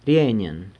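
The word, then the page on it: rienyen: San Lucas Quiaviní Zapotec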